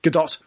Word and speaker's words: Gadot: Gadot. – It, how's it pronounced? – Gadot. – Gadot, right. Yeah.